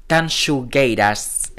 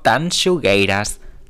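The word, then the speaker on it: Tanxugueiras: Y la siguiente es, desde luego, una de las favoritísimas junto a Rigoberta Bandini, que es Terra del grupo gallego Tanshugueiras. Espero haberlo pronunciado bien, porque según Twitter se pronuncia de esta manera, yo creo. Tanshugueiras.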